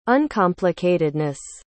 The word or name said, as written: uncomplicatedness